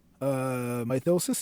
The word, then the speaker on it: Mythosis: Jumping around just a little bit, I'm curious, are you still active with Mythosis? And did I say that properly? – Mythosis,